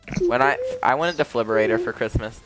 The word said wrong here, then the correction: defibrillator: Wait, am I saying that right? Hold on. – The defibrillator. – Okay, defibrillator.